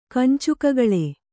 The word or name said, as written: ಕಂಚುಕಗಳೆ